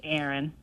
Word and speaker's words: Erin: My name's Aaron. – Aaron. – Spell that. – E-R-I-N.